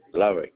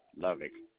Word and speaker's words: Lovic: Okay, G-A-R-R-E-T-T. Okay, thank you for that. And your name, sir? First name? – Lovick, L-O-V-I-C. – I'm sorry, could you spell that again? – Yeah, L-O-V-I-C, Lovick.